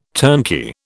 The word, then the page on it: turnkey: British English